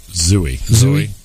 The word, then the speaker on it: Zooey: But is it Zooey Deschanel? – Zooey.